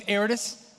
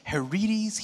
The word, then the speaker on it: Heredes: My name's Heredes. That's how you pronounce that weird name, in case you're wondering. It's like Heredes, Heredes, here.